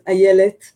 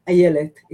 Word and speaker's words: Ayelet: So for the first time on the internet, my name is Ayelet, pronounced Ayelet. It's a Hebrew name. Sorry, but that's the way it's pronounced.